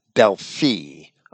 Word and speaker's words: Delphi: I should pronounce that Delphi, according to the Greek conventions.